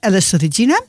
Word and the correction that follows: Elis Regina: Eh, zeg ik dat goed zo, Regina? Elis, Regina.